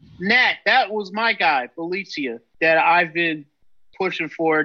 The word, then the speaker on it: Bjelica: I think it's uh, Bjelica. Bjelica, that's right.